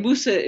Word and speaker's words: Musa: So, and Musa is the local pronunciation for in Mali for Moses.